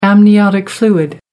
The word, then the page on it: amniotic fluid: American English